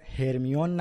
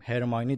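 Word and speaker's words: Hermione: علاوه بر هاگوارتس کلی دیگه از اسامی کتاب هم تغییر کرده، تلفظا دقیقا مثل نسخه بریتانیاییه، یعنی ما دیگه هرمیون نداریم، هرمیونی داریم، یا دورسلی نداریم، درزلی داریم، و کلی اسامی دیگه.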